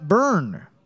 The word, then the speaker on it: Byrne: Burn tree spelled B-E-B-E-R-N-T-R-E-E. Finch spelled like the bird or the 2002 hardcore band. – Right. – And Rhonda Byrne spelled B-Y-R-N-E. – Classic. – Byrne.